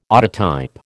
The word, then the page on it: autotype: American English